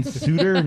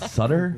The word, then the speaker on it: Suter: It's Ryan Suter, not Sutter. – Okay. – Idiot. – All right. – You don't know anything. – It's spelled the same way Sutter is. – No, Sutter has two T's.